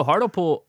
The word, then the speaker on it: Hartlepool: So John Darwin was born on August 14th, 1950 in Hartlepool. I'm sure I already screwed that pronunciation up. In County Durham. – No, Hartlepool I think is right, actually.